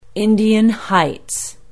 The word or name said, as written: indian heights